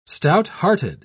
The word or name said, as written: stouthearted